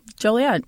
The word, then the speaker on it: Joliet: Joliet. – Oh, okay. – Shut up. It's not jolly because it's not jolly down there. – Joliet? What did I say, Joliet? – You said Joliet.